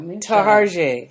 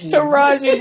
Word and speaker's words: Taraji: He talks about his relationship with Chloe, with always saying her name wrong. Tarjay. – Yeah. – Taraji, boy, we just hints and we just cut up the girl's name.